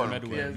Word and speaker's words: Meruem: I'm talking about I can't say his name. King. – Medwin. – Meruem. – Meruem. – King. Shout out to King. – The way they say it's Meruem. Yeah, you're right.